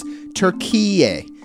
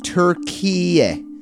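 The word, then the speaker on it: Türkiye: It wants the rest of the world to pronounce the word the same way the Turkish people do, which is Turkiye. Turkiye.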